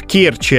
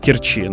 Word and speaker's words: Керчи: А нам сказали, так, главное не перепутайте, местные жители очень ревностно относятся к произношению, правильно, в Керчи, а не в Керчи.